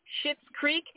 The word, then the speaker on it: Schitt's Creek: Schitt's Creek, and no, yeah. – I didn't say that word. That's how it's pronounced.